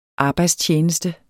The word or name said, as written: arbejdstjeneste